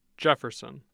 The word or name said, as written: jefferson